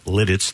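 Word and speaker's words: Lititz: and I have to pronounce this correctly, slowly, Lidditz, thank you.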